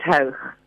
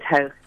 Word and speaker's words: Hough: It's Hough. – Is it Hough? Okay. – Yeah, it's Hough, yeah.